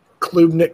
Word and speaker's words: Klubnik: Klubnik, Klubnik, Klubnik.